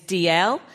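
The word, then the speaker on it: Dalziel: So my surname is DL, and people never know how to spell it or pronounce it. And so whenever I'm on the phone particularly, I automatically go into the D-A-L-Z, you know.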